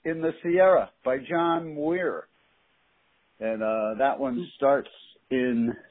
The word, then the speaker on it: Muir: Muir. – Muir.